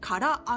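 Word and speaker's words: 空揚げ: はい、さらに空、お空の空に上という漢字のあげるを書いて、から揚げと読む当て字が使われています。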